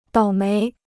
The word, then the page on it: 倒霉: dǎo méi